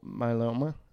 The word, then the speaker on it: myeloma: He had multiple myeloma.